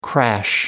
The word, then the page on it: crash: American English